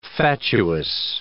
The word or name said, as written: fatuous